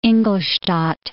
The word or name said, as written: Ingolstadt